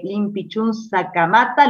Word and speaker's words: Liempichún Sakamata: Después de 125 años, el cacique Tehuelche Limpichun Sacamata, ¿lo digo bien? – Perfecto, perfecto.